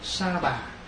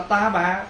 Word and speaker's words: sa bà: Nhưng mà chữ ta bà là mình phát âm đúng ra cái chữ mà tiếng Phạn đó gọi là sa ha. Ta bà là sa ha, thành thử nếu mình nói đúng là mình đọc là sa bà. Bây giờ mình đọc là ta bà, đọc nó trái rồi.